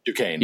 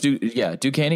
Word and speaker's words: Duquesne: A school that I actually cannot pronounce, even though I do this for a living. Is it Duquesne? – Duquesne. – Yeah, it's Duquesne at UMass.